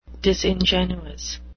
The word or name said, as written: disingenuous